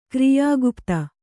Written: ಕ್ರಿಯಾಗುಪ್ತ

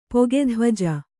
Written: ಪೊಗೆಧ್ವಜ

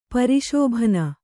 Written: ಪರಿಶೋಭನ

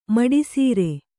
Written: ಮುಡಿಸರ

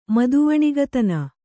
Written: ಮದವಣಿಗತನ

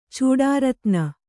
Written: ಚೂಡಾರತ್ನ

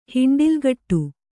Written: ಹಿಂಡಿಲ್ಗಟ್ಟು

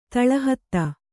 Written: ತಳಹತ್ತು

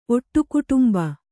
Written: ಒಟ್ಟುಕುಟುಂಬ

